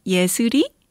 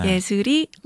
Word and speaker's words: Ye Seul: It's because my Korean name is Ye Seul, and it kind of pronounces similar to Leslie. 네. Ye Seul, Leslie.